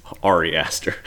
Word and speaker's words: Ari Aster: I say it wrong on purpose. It's Ari Aster.